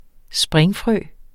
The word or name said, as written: springfrø